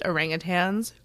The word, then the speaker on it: orangutans: I was very happy to hear that he pronounced orangutans correctly, not orangutan.